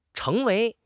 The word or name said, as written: cheng2 wei2